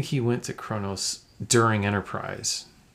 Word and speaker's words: Kronos: Kronos. Is that how you pronounce it? – Uh-huh. Yep, you got it.